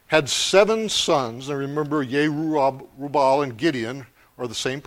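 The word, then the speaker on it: Jerubbaal: Ancient Hebrew had no J sound in it. So if that happens, that's what's going on. And don't think that, you know, I'm lost my mind or I don't know how to read. I just try to pronounce it in the way the Hebrew language is pronounced, like Yerubal. There's no J sound, so that's how we would properly pronounce that name.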